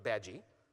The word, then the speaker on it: Badguy: And that someone else, Mr. Badgie, B-A-D-G-U-Y, it's pronounced Badgie, as we all learned from the Muppet movie, already has a presence on that system.